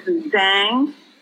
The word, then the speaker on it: Zhang: My first name is Hong, H-O-N-G, like Hong Kong. And last name is Zhang, Z-H-A-N-G, Zhang.